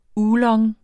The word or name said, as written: OOLONG